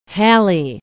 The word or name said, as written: hallie